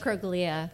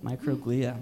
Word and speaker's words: microglia: Microglia. – Microglia, my goodness.